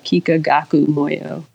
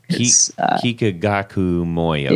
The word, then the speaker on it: Kikagaku Moyo: So I always say, if you're a pedal person, go check out Kikagaku Moyo. – Kikagaku Moyo. – It's K-I-K-A-G-A-K-U, and then second word, M-O-Y-O.